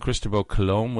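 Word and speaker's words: Cristòfor Colom: You go to Barcelona where the three ships left to find the New World, and Cristobal Colom was there. They pronounce it Cristobal Colom. That's how Christopher Columbus is pronounced.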